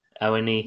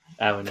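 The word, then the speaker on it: Awoniyi: Awanihi. – Yes. – Awanihi.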